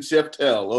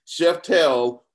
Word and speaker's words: Szeftel: So with that in mind, we've got our guest today, Jason Svetzel. Am I pronouncing that correct, Jason? – Szeftel, but pretty close. Jason Szeftel. Okay, all right. We've got Jason Szeftel with us today, and we're going to go a little global.